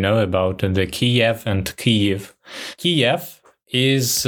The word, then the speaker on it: Kyiv: I had one question here, one distinction that I wanted to draw, because I grew up pronouncing the name of Ukraine's capital as Kiev, but I recently learned that the preferred Ukrainian pronunciation is Kyiv.